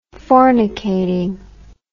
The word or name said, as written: fornicating